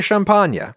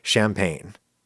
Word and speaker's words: champagne: You don't expect me to pronounce champagne champagne, even though that's how people in said wine region of northeastern France actually pronounce it. They say champagne. English speakers the world over say champagne. If I say champagne, that's weird. Even though that's the way people in Champagne actually say it.